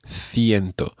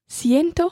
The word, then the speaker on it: ciento: Ciento starts with C-I, and therefore in Spain it's pronounced ciento. But in Latin America it would be pronounced... – Ciento.